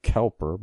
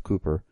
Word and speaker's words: Cowper: And I was just going to say the great hymn writer William Cowper, which is spelled like Cowper, but it's actually pronounced Cooper.